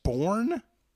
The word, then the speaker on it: sporran: The Scottish sporran? – Sporran. – What is that? Spell that? – S-P-O-R-R-A-N.